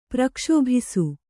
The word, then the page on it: ಪ್ರಕ್ಷೋಭಿಸು: Kannada